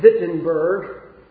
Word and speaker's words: Wittenberg: The day that Martin Luther nailed his 95 theses to the door of the church at, I believe it's pronounced in German, Wittenberg. The W is a V sound.